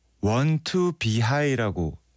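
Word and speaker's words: 원투비H: 이번에 준비된 곡은 원 투 비 하이라고 읽어야 된대요. 원 투 비 H인데 원 투 비 하이의 러버스 플레이어, 연인들의 기도.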